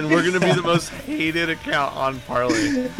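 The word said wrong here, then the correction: Parler: Parlor. I didn't even say it right, dude.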